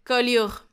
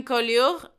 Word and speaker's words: Collioure: No, no lo quiero pronunciar mal, perdonadme, francófonos, hablantes del francés, Collioure. Murió en Collioure y allí lo enterraron.